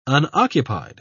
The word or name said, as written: unoccupied